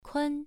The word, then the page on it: kun: 1